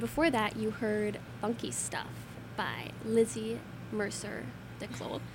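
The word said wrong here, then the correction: Mercier Descloux: How do you say her name? – It's Lizzie Mercier DeClaude.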